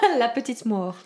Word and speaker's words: la petite mort: They often use it to describe an orgasm, but it's called le petit mort. And I know I'm not a French speaker, so I probably mangled that.